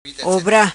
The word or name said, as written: Obra